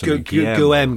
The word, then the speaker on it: Guillaume: I don't know how you pronounce it. Hang on. – Oh, is it Guillaume? – Yes, Guillaume, because he's French.